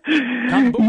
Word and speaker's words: kombucha: Is that how you say it? – Kombucha. – Yes, kombucha. – It is.